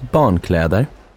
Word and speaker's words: barnkläder: Var finns barnkläder? Var finns barnkläder? – Well done. Did you notice how you stressed the two syllables in the compound word for children's clothes? – Barnkläder.